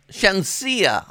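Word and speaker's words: Shenseea: And Shanisa. Shania. – Hi, Shanisa. – Shania. S-H-E-N. Shensia. I'm sorry, Shensia. I said it wrong.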